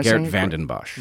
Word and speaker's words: Geert Vanden Bossche: Because one of the things that you got in trouble for was uh, how do you say his name? Geert Vanderbosch. – Am I Geert Vanden Bossche.